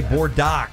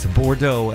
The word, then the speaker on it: Bordeaux: And you don't want to say Bordeaux. It's Bordeaux.